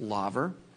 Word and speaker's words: laver: Some people pronounce it laver, but it's actually pronounced laver.